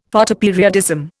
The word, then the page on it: Photoperiodism: Indian